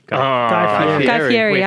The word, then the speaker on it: Fieri: So Unwrapped or Guy Ferrier? – Guy Fieri. – I always say it wrong.